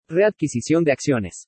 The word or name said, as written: READQUISICION DE ACCIONES